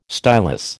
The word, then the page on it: stylus: American English